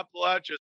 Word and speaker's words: Appalachia: Is it Appalachia? Is that how you say it? – Yeah, Appalachia.